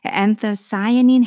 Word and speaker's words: anthocyanin: 그래서 anthocyanin이라고 읽어요. 영어로는. 자, 어, 강세는 음, 뒤에 있어요. 뒤쪽에. cyanin, A쯤에 있겠네요. anthocyanin 해가지고 이게 뭐냐면 안토시 칼라내는 애들 있죠.